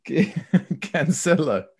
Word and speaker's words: Cancelo: Cancelo.